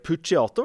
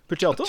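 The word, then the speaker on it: Puciato: Greg, Pu eh, Greg Pucciato, var det det var? Pucciato? Jeg tror det er sånn uttalelse. Ja.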